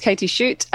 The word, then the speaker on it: Katy Schutte: So my name's Katy Schutte, as you beautifully pronounced, by the way.